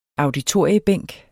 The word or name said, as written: auditoriebænk